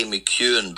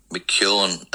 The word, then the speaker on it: McKeown: They say McKeown, but it's actually McKeown, as we say in Ireland. – McKeown. – Yeah, McKeown. That's it, yeah. The other girl, Emma McKeown, she's McKeown, but... – Yes. – Keely is spelt different, so we say McKeown in Ireland.